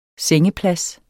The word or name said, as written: sengeplads